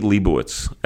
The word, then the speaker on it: Lebowicz: And his name is Eli Lebowicz. Eli, welcome to the Jewish Link. – Thank you. Shkoyach on the pronunciation.